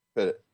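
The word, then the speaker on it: Pettitte: What, how do you say his name? – Pettit.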